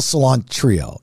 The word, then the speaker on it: Cilantrillo: Well, I'll tell you. It's a C-I-L-A-N-T-R-I-L-L-O. Cilantrillo. Like cilantro, okay.